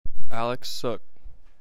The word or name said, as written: Alex Suk